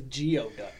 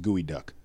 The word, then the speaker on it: geoduck: It looks like it says geoduck. – Yeah, geoduck. Yeah. – So it's G-E-O. – Yeah, but it's pronounced gooey duck.